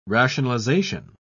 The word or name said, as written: rationalization